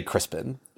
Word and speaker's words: Crispian: Except it's spelled Crispian. – Yes, it is. But they just say Crispin.